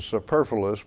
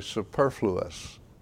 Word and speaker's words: superfluous: We want to pronounce it superfluous, but it's actually superfluous.